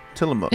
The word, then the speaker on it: Tillamook: Oh, Tillamook.